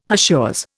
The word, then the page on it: hachures: Indian